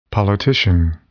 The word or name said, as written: politician